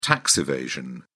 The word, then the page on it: tax evasion: British English